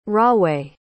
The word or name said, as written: rahway